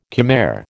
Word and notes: Khmer: American English

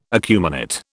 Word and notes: acuminate: British English

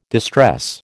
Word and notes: distress: American English

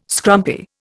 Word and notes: scrumpy: Indian

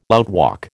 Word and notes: outwalk: American English

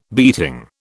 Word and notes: beating: British English

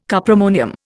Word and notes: cuprammonium: Indian

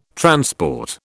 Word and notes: transport: British English

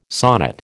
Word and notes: sonnet: American English

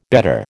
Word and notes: bedder: American English

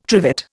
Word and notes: trivet: Indian

